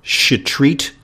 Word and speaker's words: Shetreat: Thanks for joining us, Dr. Maya Shetreat Klein. I'll spell it once again, S-H-E-T-R-E-A-T. Shetreat. The way you can remember that is that she probably should have been a gynecologist. Shetreat. Dash Klein, K-L-E-I-N.